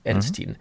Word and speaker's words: Edelstein: Lisa Edelstein, habe ich das richtig ausgesprochen? Lisa Edelstein. – Edelstein.